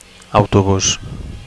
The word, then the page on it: autobus: Catalan